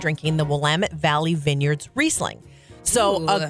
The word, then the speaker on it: Willamette: But it's Willamette. Rhymes with damn it. And they actually have bumper stickers in Oregon. It'll just say, it's Willamette, damn it.